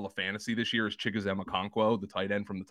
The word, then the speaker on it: Chigoziem Okonkwo: Uh, Chigoziem Okonkwo.